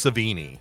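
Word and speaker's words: Sevigny: Chloe, I can never pronounce her surname. Is it Savogny? – I think it's Savini. – Savini, that sounds better. Because my one sounds like a wine.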